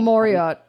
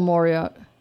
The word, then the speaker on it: Mauriat: Oh, Paul Mauriat. Yeah, Paul Mauriat.